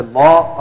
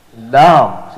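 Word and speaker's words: Lam (لام): Lam. So you wouldn't say la, you would pronounce it like lam.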